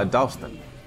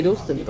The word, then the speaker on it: Dalston: Dalston.